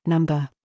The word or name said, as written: STRUT